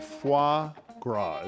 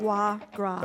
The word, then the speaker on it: foie gras: By the way, I embarrassed myself, so let me say it clearly. Foie gras is how you say it. – Foie gras.